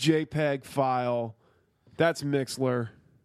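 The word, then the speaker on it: Mixlr: Mixler. You always fuck it up. – What? Wait. That R doesn't deserve pronunciation. That's just a fucking. That's and where's the L? We're just leaving the L out or like. – It's M-I-X-L-R.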